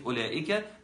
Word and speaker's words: أولئك: كلمة أولئك مفيش فيها واو.